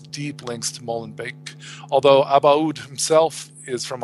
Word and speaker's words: Molenbeek: And that guy was also from Molen, Molenbeek, if I'm pronouncing it correctly, that suburb of Brussels, which mm.